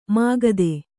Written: ಮುಗ್ಧ

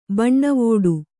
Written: ಬನಾವಟಿ

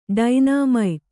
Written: ಡೈನಾಮೈಟ್

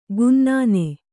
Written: ಕೆನ್ನನೆ

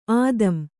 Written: ಆದಂ

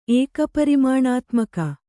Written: ಏಕಪರಿಮಾಣಾತ್ಮಕ

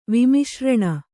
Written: ವಿಮಿಶ್ರಣ

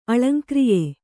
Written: ಅಳಂಕ್ರಿಯೆ

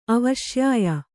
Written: ಅವಶ್ಯಾಯ

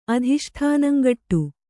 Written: ಅಧಿಷ್ಠಾನಂಗಟ್ಟು

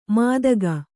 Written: ಮಾದಗ

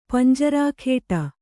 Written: ಪಂಜರಾಖೇಟ

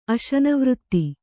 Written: ಅಶನವೃತ್ತಿ

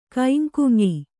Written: ಕೈಂಕುಂಯಿ

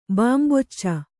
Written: ಬಂಬೂಚು

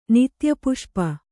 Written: ನಿತ್ಯಪುಷ್ಪ

